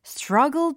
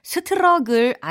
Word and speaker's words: struggle: 왜냐면 철자가 s-t-r-u-g-g-l-e struggle, struggle, struggle 이거든요. 스트러글 아니고 스트러글 아니고 struggle, struggle, struggle, struggle 하고 계세요?